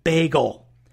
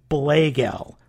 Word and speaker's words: Blagel: When I originally saw the show, I thought his name was Bagel, as in Bagel and Lox. No, that was a translation error. It's actually supposed to be pronounced Blagel, like Blade and Gel. B-L-A-G-E-L.